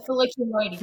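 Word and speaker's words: filiculoides: You describe it as recycling oil from contaminated water using Azolla folliculoids, am I saying that correctly? Folliculoids, to create a biodiesel blend. – So close, folliculoides. – Folliculoides, okay.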